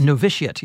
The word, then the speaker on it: Novitiate: Now, am I saying this right? Novitiate? – Novitiate, yes.